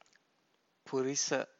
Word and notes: purisa: Pali

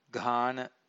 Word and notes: ghana: Pali

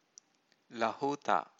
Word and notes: lahuta: Pali

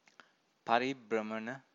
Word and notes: paribramana: Pali

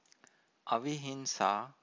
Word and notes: avihinsa: Pali

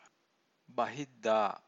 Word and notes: bahiddha: Pali

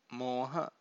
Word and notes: moha: Pali

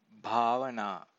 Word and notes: bhavana: Pali

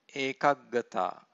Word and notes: ekaggata: Pali

